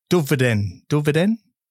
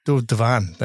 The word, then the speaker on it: Duvdevan: This unit, though, however, it's a special forces unit, Duvdevan. No, I said it wrong. – Duvdevan. – That's fine. I got it eventually.